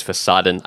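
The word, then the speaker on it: Fassaden: And I also mispronounced in the episode about ivy and monstera, I mispronounced the word fasaden because there was a cool website I found called Fassadengrün, which is a website about green facades. And that's the German word for facade, is fasaden.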